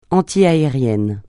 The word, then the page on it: antiaérien: francais